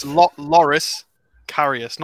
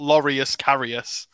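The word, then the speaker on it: Loris Karius: It's Loris Carius, not Laureus Carius.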